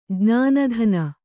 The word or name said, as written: ಜ್ಞಾನಧನ